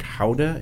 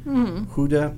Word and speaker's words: Gouda: Also, the town that Gouda cheese comes from, I believe it's pronounced something like Houda in Dutch, Houda possibly, that